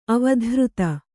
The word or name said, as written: ಅವಧಾರಿತ